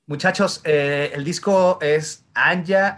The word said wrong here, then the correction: Ashna: Anja, ¿cómo se pronuncia eh, y cuándo sale? El disco se llama Ashna.